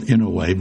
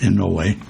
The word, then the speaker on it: Inouye: You'll hear him called Inouye by the network news guys. They mispronounce it, and he's too polite to correct them. It's pronounced in no way.